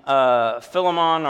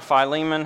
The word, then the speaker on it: Philemon: The others, Ephesians, Colossians, and Philemon or Philemon, however you want to. Just don't say Philemon, please. I pronounce Philemon.